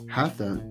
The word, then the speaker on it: hatha: Hatha, the correct pronunciation, hatha.